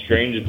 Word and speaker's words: Strainge: I know it's more of an Amelia's wheelhouse, but Sierra Nevada has come out with a new product called Strange Beast. It's spelled slightly different, S-T-R-A-I-N-G-E, so like strange.